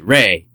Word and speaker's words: Re: Ra is sometimes A-E and pronounced Re.